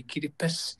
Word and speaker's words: Kiribati: it would actually get around that problem of people mispronouncing all too often, including other Pacific Island leaders, Kiribati. – That's right, yeah. I think Tongaru would be easier to pronounce rather than Kiribati, and then you have to correct them. Oh, it's just we don't have the S in our language, so we say Kiribati.